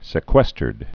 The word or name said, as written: sequestered